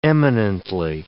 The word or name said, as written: eminently